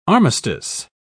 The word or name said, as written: armistice